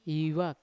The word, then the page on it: ivok: Yawa